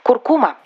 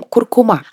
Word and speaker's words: куркума: Куркума. – Верное произношение этого слова встречается редко. Мы чаще всего говорим «куркума». Однако правильно «куркума». Запоминаем с помощью стишка. Плов кладу 100 граммов изюма, а еще нужна куркума.